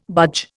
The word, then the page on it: budge: Indian